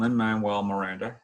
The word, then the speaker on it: Lin-Manuel Miranda: And Hamilton, Lin-Manuel Miranda, hey, I finally got his name right, because I kept on saying Lin's name wrong previously.